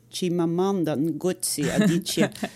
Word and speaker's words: Chimamanda Ngozi Adichie: För övrigt så jag måste säga att ditt uttal var ju väldigt snyggt. Chimamanda Ngozi Adichie.